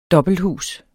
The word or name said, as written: dobbelthus